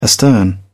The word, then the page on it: astern: British English